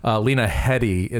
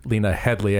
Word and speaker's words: Lena Headey: Number one, well, for me, I think, Lena Headey, and not Lena Headley, how I think I pronounced her name earlier.